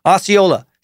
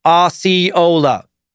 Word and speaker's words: Osceola: She follows with, "Dude, how do you say the same word 874 times, but only pronounce it correct 4 times? Osceola, say it with me. Osceola.